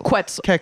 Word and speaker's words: Quetzal: Quetzal or Quetzal? – Quetzal. – Is a... – Quetzal. – Quetzal. – Like Wetzel's pretzels, but Quetzals.